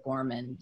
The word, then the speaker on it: Gorman: And so, you know, if you just search my name, Gayla Gorman, you'll come up with a lot of ways to find me.